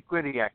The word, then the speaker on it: Quinnipiac: Check the Quinnipiac polls. Mm-hmm. I believe I'm saying that right. Those are the most scientific available. – Quinnipiac, yeah.